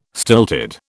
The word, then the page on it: stilted: British English